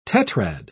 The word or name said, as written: tetrad